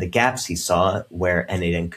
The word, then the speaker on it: n8n: That's pronounced N8N, not Naden, as I had incorrectly assumed.